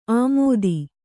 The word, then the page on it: ಆಮೋದಿ: āmōdi